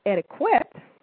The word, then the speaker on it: etiquette: And that kind of gives you a clue on how to pronounce the word, even though it's spelled E-T-I-Q-U-E-T-T-E. It's not pronounced etiquette. It is pronounced etiquette. And I always say that if you say the word slowly, you will hear yourself say ticket, etiquette.